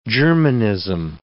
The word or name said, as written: Germanism